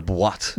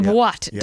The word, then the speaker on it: boîte: The Bouat. – Yep. – Is that how you pronounce it? – The boîte. – The boîte.